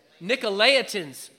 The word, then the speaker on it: Nicolaitans: Pastor Mark, how do you say that? Nicolaitans? Nicolaitans, praise God.